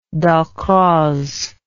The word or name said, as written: Dalcroze